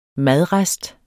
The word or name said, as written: madrest